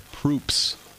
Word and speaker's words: Proops: There's an R in there. – Good. – So please pronounce the R. Greg Proops from that show here today.